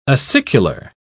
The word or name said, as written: acicular